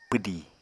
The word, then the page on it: pwdì: Apatani